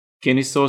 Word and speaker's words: Kennesaw: So you're studying currently at the Kennesaw State University.